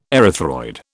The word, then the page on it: erythroid: British English